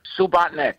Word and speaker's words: Subotnik: Subotnik.